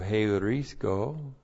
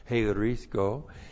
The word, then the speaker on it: heurisko: And then we have the verb heurisko. Looks like that in the Greek. H-E-U-R-I-S-K, long O, heurisko.